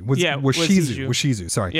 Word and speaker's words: Washizu: Well, on some level, you know, once the prophecy begins to come true, Macbeth is doomed for the reasons that or Wazishu, I just want to make sure I'm pronouncing this correctly. Excuse me. Washizu.